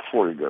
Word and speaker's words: фольга: В словарях, даже в словарях там, моей молодости, например, существовало слово «фольга» вот с этим ударением, да? Но я не помню, чтобы кто-нибудь реально так произносил. Вот, оказывается, нормой является «фольга». Вы скажете «фольга»? Я нет.